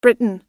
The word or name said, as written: britain